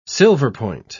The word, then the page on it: silverpoint: British English